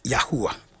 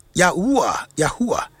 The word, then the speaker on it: Yahuah: The father's name is Yahuwah. A genuine Yahuwah. The father's name is Yahuwah. Y-A-H-U-A-H. Yahuwah. It is spelled, say this is spelled in the Pelo Hebrew, a yod he wow hey.